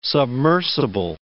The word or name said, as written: submersible